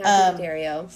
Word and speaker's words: Daddario: Daddario? – Mm-hmm. Daddario.